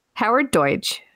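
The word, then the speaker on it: Howard Deutch: So Howard Deutsch, who directed this, I'm probably saying his name wrong and I'm really sorry.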